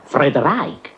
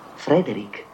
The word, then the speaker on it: Frederick: Allora dice anche Friedreich. – No, Frederick. – Beh, perché non è Friedreich Frankenstein? – Non lo è Frederick Frankenstein.